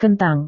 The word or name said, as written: kentang